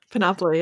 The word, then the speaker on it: Panoply: Panoply, yeah.